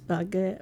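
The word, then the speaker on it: Bargara: And Bagara was originally the first three letters of Brolin and the last letters of Woongarra. But now it's Bagara. How do they say it? Bagara. – Bagara.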